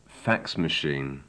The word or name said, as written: fax machine